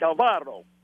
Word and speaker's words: Alvaro: You got to roll the R, too, like Alvaro.